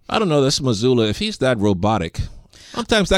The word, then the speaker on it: Mazzulla: I think guys like Mazzulla...